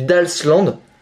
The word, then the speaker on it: Dalsland: Genre, tu regardes la carte du, de la région du Dashland, non, je le prononce mal, c'est du Dalsland. Il y a D-A-L-S-T-A-N-T-D.